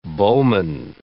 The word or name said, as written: bowman